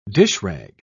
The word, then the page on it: dishrag: British English